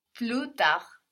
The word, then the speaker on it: plus tard: Almost, we don't have the S though. – Plus tard. – Plus tard. Good. – Plus tard. – Plus tard. So again, we don't pronounce that final consonant, no. We have plus written, like plus in English, but we pronounce plus. Plus tard.